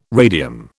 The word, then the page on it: radium: British English